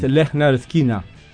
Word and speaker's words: Lynyrd Skynyrd: Ahí ponen cómo se pronuncia, pero se llama el pronouncer. – Claro, porque es una palabra difícil. – Es Lynyrd Skynyrd. Lynyrd Skynyrd. Ahí está fonéticamente puesto.